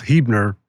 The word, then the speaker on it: Huebner: Hubner Marketing. Did I pronounce all that correctly? Pretty close, Huebner, but very close, very close.